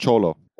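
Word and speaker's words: Xolotl: Very similar to in Egyptian mythology, the dead are aided by the psychopomp Zolotl. So that's the dog-headed, I believe. – Cholo. – Dog-headed. – It's pronounced cholo. Origin of the word, yeah. It comes from cholo.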